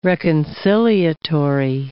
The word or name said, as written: reconciliatory